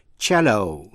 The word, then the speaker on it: cello: Sang đến phần C của bài học, ta ôn lại có vài chữ gốc tiếng Ý, trong đó C đọc là ch. Thí dụ, cello, C-E-L-L-O,